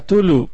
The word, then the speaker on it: Cthulhu: No, te Brokeback Chulu. – ¿Qué pasó? ¿Cómo se pronuncia? – Cthulhu. – Cthulhu. – Cthulhu. – Cthulhu. – Ah, se oye bien, se oye mejor que Chulo, definitivamente.